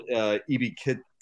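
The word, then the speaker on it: Ebiketie: Arnold Ebiketete, is that how you pronounce it? The Penn State edge rusher. – Yep.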